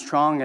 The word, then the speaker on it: Truong: My last name is Truong, and that's just the English pronunciation,